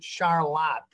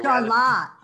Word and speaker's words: Charlotte: I'm here today with Prey Funeral Home in Charlotte, Michigan. – Hello. How are you? And I gotta correct your pronunciation. We pronounce it Charlotte, the English way. – Charlotte.